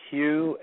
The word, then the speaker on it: Hugh: My name is spelled Hugh, H-U-G-H. The last name is Charlson, T like Tom, R-A-U-L-S-E-N like Nancy.